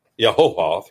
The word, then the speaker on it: Yehovah: That's the holy name of God, as it is called, Yehovah. Yehovah, three syllables. Again, anglicized, Jehovah.